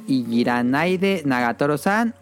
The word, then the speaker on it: Ijiranaide Nagatoro-san: なだけ台無しにしたいの石だ石だ石だね君にだけまだ知らない青い春目覚め Escucharon Easy Love de Sumire y la serie es Ijiranaide Nagatoro-san o como Déjame en paz Nagatoro-san, aunque yo no sé japonés y no sé si lo estoy diciendo bien. – Sí, no te preocupes, súper bien tu pronunciación.